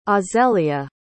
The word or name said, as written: azelia